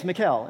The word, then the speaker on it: Mikel: Like, my name is Mikel, and it's spelled M-I-K-E-L.